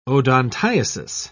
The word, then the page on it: odontiasis: British English